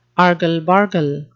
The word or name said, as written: argle-bargle